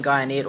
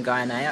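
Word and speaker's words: Guyenet: Is it Stephen Guyanet or Stephen Guyanet? I never remember how to pronounce his last name. – DNA, like DNA, but with a G.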